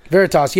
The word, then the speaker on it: Veritas: Is that how you say it? – Veritas. Yeah. It's Latin.